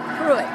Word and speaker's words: Pruitt: D-A-V-E, David Sandifer, S-A-N-D-E-F-U-R. – Okay, can I get your name, please? – Marie Pruitt. – Can you spell it? – M-A-R-I-E-P-R-U-I-T-T.